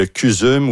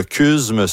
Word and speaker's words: CUSM: Le CUSUM ou le CUSM, selon la prononciation qu'on choisit, est associé à l'Université McGill.